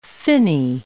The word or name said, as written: finny